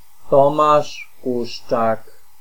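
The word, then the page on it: Tomasz Kuszczak: ˈtɔmaʃ ˈkuʃtʃak; Polish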